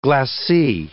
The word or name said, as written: glacis